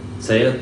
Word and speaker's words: Sayeret: Sayeret Matkal.